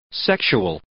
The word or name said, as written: sexual